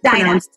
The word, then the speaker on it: Daina: It's a Lithuanian name, and Daina is a super common name over there, and it's spelled like D-A-I-N-A, like my name. But yeah, in the States, it's like a really hard thing because, yeah, I guess it's pronounced like D-I-N-A-H.